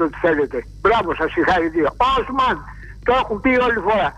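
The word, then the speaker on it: Osman: Οι τουρκικές λέξεις τονίζονται στο τέλος. Οσμάν, σ' άκουσα πάλι.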